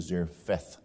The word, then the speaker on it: Zarephath: The proper pronunciation is Zarephath, the place from which Elijah was coming from.